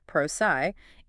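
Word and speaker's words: Prosci: This framework recommends tapping into a proven model like the PROSI, pronounced PROSI, 80-CAR process.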